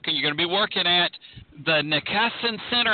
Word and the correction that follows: Niskanen Center: I may have said that wrong. Tell us about your work there at that center. – Yeah, so it's actually the Niskanen Center, and you know, it's like everything else I work for, hard to pronounce.